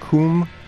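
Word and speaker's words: COUM: And you came out of another project called, how did you pronounce it, Coombe? – Right.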